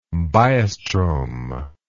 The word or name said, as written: biostromal